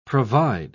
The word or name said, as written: provide